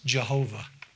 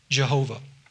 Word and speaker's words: Jehovah: But if you look at the consonants of Yahweh and you add the vowels underneath it of Adonai, you pronounce that mixture of consonants and vowels, Jehovah.